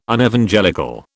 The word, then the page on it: Unevangelical: British English